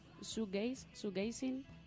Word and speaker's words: shoegazing: que esta vez vamos a hablaros del shoegaze. No sé si lo he dicho bien, ¿lo he dicho bien? Shoegazing, está bien dicho, vale.